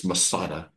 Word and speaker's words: Metzada: We say Masada. We do two soft S, but it's more of a TZ in the Hebrew. Masada. And so here it's a plural. It's Masadit. But up earlier, it was singular, and it's Matsada, Matsada.